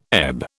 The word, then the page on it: ebb: British English